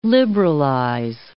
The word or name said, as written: liberalize